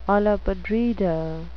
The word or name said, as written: olla podrida